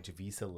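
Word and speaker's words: Javicia: To be clear, Kane is no longer Batwoman, who is now played by Javisa Leslie. I think that's how you say her name. – Yeah.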